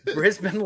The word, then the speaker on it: Brisbane: Brisbane Lions I'm taking as my number three, and they're in third place.